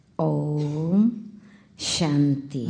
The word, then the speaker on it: Om Shanti: And this in Sanskrit or Hindi, it is like this spoken, Om Shanti. – Em Hindi, no sânscrito, é assim que se fala, se pronuncia, como ela falou. – Om Shanti.